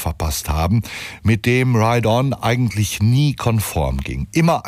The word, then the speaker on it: Riton: Er schreibt sich nämlich R-I-T-O-N und ich neige manchmal zu so einer leicht deutschen Aussprache und habe ihn womöglich jahrelang Reaton ausgesprochen. Er spricht sich aber Ride On und hat,